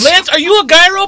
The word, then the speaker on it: gyro: G-Y-R-O. What is the correct way to pronounce it? – So it's pronounced gyro, but if you say gyro, the person at the cash will understand you. So whatever way you say it, it's acceptable.